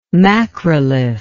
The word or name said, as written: macrolith